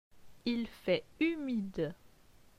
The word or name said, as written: Il fait humide